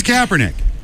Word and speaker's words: Kaepernick: So they decided to have Colin, I can never pronounce this dude's name. – It's Kaepernick.